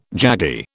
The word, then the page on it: jaggy: British English